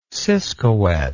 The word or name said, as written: siscowet